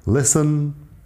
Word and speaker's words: listen: Listen, la T no suena.